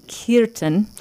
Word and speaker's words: Kirtan: So Kirtan, which actually, if we were to say it correctly, would be Kirtan.